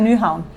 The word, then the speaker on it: Nyhavn: Und alle, die nach Nyhavn, ich glaube, so spricht man das ja aus. – Ja, nur 5 Minuten spazieren zum Nyhavn. Ja.